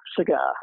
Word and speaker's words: cigar: Now you take a word like cigar in English. Now the first syllable is going to be reduced. It's cigar, right? And you have exactly the same word in Dutch, but it's sigaar, right? So the first syllable is not reduced. It's secondary stress.